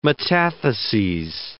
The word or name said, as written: metatheses